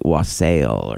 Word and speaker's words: wassail: With is that even how that word is pronounced? – Oh, great. – No, it's W-A-S-S-A-I-L. It looks like wassail or something.